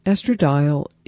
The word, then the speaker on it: estradiol: E-S-T-R-A-D-I-O-L, estradiol, estradiol.